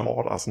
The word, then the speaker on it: Taught Us: 1980 haben sie ihr erstes Album gemacht, äh, Songs The Lord Taught Us. Hab ich das richtig ausgesprochen? Ja. Taught Us, ne? Das sagt man.